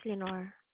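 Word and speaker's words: Leonor: My name is Lenore. – How do you spell that, ma'am? – It's L-E-O-N-O-R. – L-E-O-N-A-R, ma'am? – Yes.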